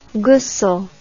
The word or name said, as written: ghusl